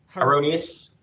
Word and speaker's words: Heironeous: Heironeous.